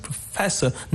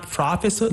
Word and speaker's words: professor: Even like the word professor, the stress is on the second syllable, professor, not professor, no, but professor.